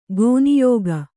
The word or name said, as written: ಕನ್ಯಕೆ